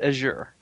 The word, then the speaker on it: Azure: I pronounce it Azure.